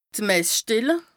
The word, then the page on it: d' Mess-Stelle: Bas Rhin